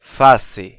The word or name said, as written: fase